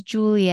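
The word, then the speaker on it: Giulia: Anyway, so it's this girl named Julia, but it's spelled in the Italian way of Julia. So it's G-I-U-L-I-A. So her name is Julia, and she arrives at Halstead Manor.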